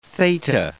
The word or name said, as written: theta